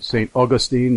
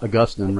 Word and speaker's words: St. Augustine: And I'm assuming from my memory that that's the way the Floridians pronounce it, St. Augustine, not Augustine, right? – Yeah, theologians pronounce it Augustine.